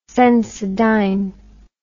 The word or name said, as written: sensodyne